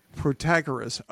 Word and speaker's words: Protagoras: Oh, Protagoras.